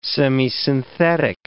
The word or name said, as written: semisynthetic